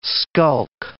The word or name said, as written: skulk